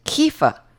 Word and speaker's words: Kiefer: Today I'm really honored to be speaking with Anna and Matt Kiefer.